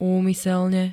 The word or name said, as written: úmyselne